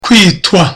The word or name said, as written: kwitwa